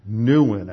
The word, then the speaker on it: Nouwen: Well, it turns out that Chuck Colson has been promoting the writings of a man named Henry Nouwen, I think is how you pronounce it, Nouwen.